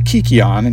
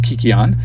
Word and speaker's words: kykeon: This was known as the kikion, and you have to forgive my pronunciation here. It is spelled sometimes K-Y-K-E-O-N or K-U-K-E-O-N. I'm going to pronounce it as kikion.